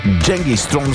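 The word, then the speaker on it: Genghis Tron: Genghis Throne всъщност е истинското им произнасяне, произношение.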